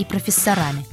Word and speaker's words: профессора: Говорить нужно «доктора», «профессора» и «директора». Других вариантов ударения в этих словах быть не может.